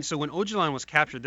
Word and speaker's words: Öcalan: 2013, where it looked like the Kurds and, I mean, Akkwan, what the guy, the leader of the PKK? – Öcalan. – Sorry, I got his name wrong.